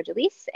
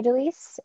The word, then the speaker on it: Délice: But my name is Amber Delise, and I'm on Twitter as Meet Amber Delise, and my last name is spelled Delice.